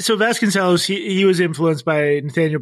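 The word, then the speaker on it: Vasconcellos: Yeah, I think it's pronounced Vasconcelos, but I'm legendarily bad at pronunciation. So well, I'm just... – Okay. As a musician, I'm going to say cellos. So Vasconcelos, Vasconcelos.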